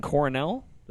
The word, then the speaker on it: Coronel: So I'm saying Coronel. That sound about right? – Yeah, like the Ivy League, like where Andy Bernard from The Office went to school.